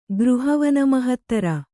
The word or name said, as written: ಗೃಹವನಮಹತ್ತರ